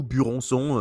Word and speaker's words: Buronson: quand on dit euh, Bronson en japonais, en fait, pour le prononcer, on dit Bronson. Et du coup, Buronson, euh, tout simplement.